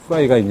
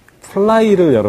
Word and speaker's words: 플라이: 이 야구에서 왜 후라이가 있냐? 플라이를 여러분들 생각하시면 됩니다. 플라이. 일본 사람들이 다 발음을 못해가지고, 받침 있는 걸 잘 발음을 못합니다. 그것이 플라이가 후라이가 된 것입니다.